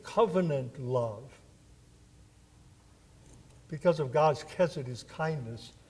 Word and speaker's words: chesed: The Hebrew word translated as kindness here, it's pronounced chesed.